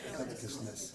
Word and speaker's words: covetousness: How do you say that? Somebody help me out. – Covetousness. – Thank you. Non-covetousness.